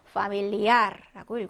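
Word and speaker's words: familiar: 끝철자 확인하니까 에레로 끝났네요. 자, 에레는 그 밖의 자음에 해당이 되니까 어디에 강세가요? 맨 끝음절에 강세가 오게 됩니다. 자, 약강이 만났으니까 강모음 위에 강세를 넣어서 familiar라고 읽어주시면 되고요.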